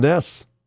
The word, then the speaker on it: Njos: It was spelled like N-J-O-S. – Yeah, Calvin Ness. – And I was like, how do you get Ness out of that? – Yeah, that's how the Nesses are.